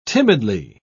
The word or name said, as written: timidly